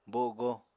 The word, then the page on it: bogo: Apatani